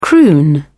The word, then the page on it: croon: British English